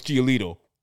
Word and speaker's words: Giolito: Like you didn't pronounce that correctly. – Oh, sorry. Lucas Giolito.